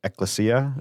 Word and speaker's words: ecclesia: but yeah, anything, you know, he went over those three, called it the, I might pronounce this wrong, ecclesia. – Yeah.